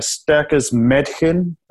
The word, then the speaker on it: Starkes Mädchen: I'm going to mess up this pronunciation, but Starkes Mädchen, I've definitely butchered that. – I'm a massive fan of Die Totenhosen, so I can tell you that you pronounce that perfectly.